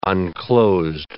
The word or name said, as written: unclosed